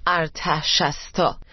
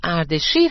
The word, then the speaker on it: اردشیر: این اسم به شکلهای مختلفی نوشته و تلفظ میشه مثل ارتشستا و میتونه به صورت اردشیر هم تلفظ بشه